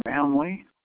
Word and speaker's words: Manley: Gary Manley. – And that's G-E-R-R-Y? – G-A-R-Y. – G-A-R-Y. – M-A-N-L-E-Y.